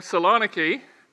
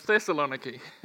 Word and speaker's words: Thessaloniki: And then we were talking about Thessalonica, and Thessalonica became Saloniki, and now it's being pronounced again as Thessaloniki. So the names sort of shift and change over time.